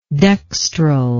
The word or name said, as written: dextrally